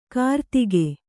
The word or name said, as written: ಕಿರುದೇಗು